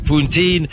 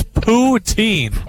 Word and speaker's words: poutine: You had the steak poutine, which is... why do you always laugh every time I say that? – Because you said it wrong. It's poutine.